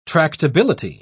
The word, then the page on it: tractability: British English